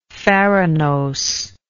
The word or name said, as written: farinosely